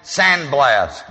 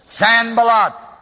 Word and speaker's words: Sanballat: Many people call him Sandblast, but it's spelled a little differently. Sanballat.